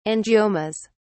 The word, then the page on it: angiomas: British English